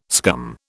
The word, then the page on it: scum: British English